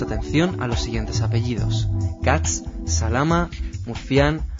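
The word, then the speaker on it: Kac: Hay apellidos derivados de iniciales hebreas como Katz o Kak, que en polaco se pronuncia Katz.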